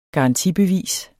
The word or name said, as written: garantibevis